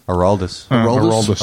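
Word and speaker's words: Aroldis: Andreas? – Araldis.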